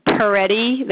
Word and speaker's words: Peretti: So the name is James Peretti. That's P like Peter, E-R-E-T-T-I. The address is 1 Balsam, B-A-L-S-A-M, Lane, North Easton, E-A-S-T-O-N, Massachusetts, 02356.